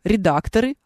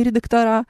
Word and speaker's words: редакторы: Правильно говорить редакторы, а не редактора.